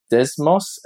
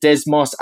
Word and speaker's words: Desmos: The other thing is that over the past year or so, I've upskilled my computation layer ability on Desmos. As we pronounce, we in Australia, we pronounce it very differently than across the Atlantic. – Let's hear it. – So we pronounce it Desmos.